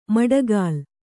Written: ಮಡಗಾಲ್

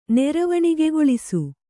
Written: ನೆರವಣಿಗೆಗೊಳಿಸು

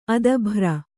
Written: ಅದಭ್ರ

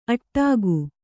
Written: ಅಟ್ಟಗೆ

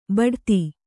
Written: ಬೊಟ್ಟೆತ್ತು

